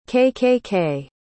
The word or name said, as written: KKK